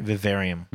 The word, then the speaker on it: Vivarium: So first on our trailer list is Vivarium. Am I saying that right? Vivarium. – Mm-hmm.